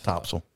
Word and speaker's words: Topsail: You got to say it like a native. – Topsail. – Topsail. – Yeah. Topsail. – Yeah. It's weird. – That's what they say. That's how they say it. Topsail. – Topsail. Yeah. It's like there's no A in it. It's just Topsail.